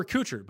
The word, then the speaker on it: Kuchar: Any love for Kuchar, but it's spelled like Ashton Kutcher.